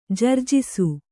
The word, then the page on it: ಜರ್ಝಿಸು: jarjhisu